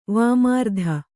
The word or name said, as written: ವಿಮರ್ದ